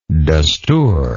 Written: dustoor

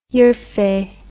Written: urfe

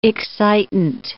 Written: excitant

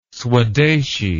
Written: swadeshi

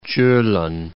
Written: Kjolen Mountains

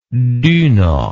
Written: duna

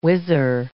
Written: whizzer

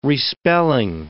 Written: respelling

